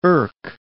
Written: irk

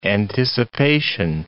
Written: anticipation

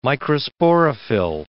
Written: microsporophyll

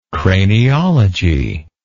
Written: craniologist

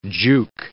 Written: juke

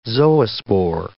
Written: zoospore

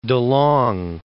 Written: De Long

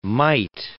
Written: might

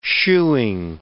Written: shoeing

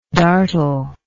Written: dartle

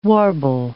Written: warble

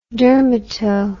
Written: dermato

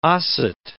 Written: Osset

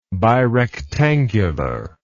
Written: birectangular

